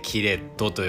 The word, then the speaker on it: キレット: キレットってね、日本語なんですよ。漢字で書くとね、あの、切れる扉の塔ですね。キレトと書いてキレットと呼びます